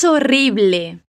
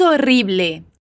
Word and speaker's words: horrible: Es horrible. Com esses dois R's vibrantes e fortes mesmo. Es horrible.